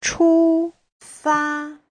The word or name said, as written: chu1fa1